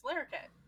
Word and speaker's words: Larroquette: It's Larroquette.